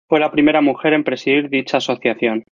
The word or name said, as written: presidir